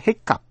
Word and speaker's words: hiccough: Nhưng chữ hiccup, H-I-C-C-O-U-G-H, hiccup, hay là viết là hiccup, H-I-C-C-U-P, nghĩa là nấc, phát âm hiccup và tận cùng âm p.